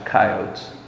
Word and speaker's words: coyotes: And the shepherd also protects the sheep from, it's pronounced in the West, coyotes.